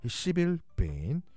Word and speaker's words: 시빌 베인: 발음 조심하겠습니다. 시빌 베인. 발음 점 하나 붙이면 큰일 납니다. 시빌.